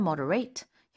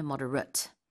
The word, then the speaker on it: moderate: For example, 뭐 이제 좀 부드럽게 말을 해보세요라고 할 때, 그렇게 쓸수 있는데 동사일 때는 moderate, 형용사일 때는 moderate. So the pronunciation is different.